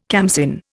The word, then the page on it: khamsin: Indian